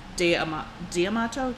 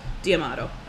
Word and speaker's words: D'Amato: D'Amato, I think is how they say it. It's a hard D. – Sounds right. – D'Amato.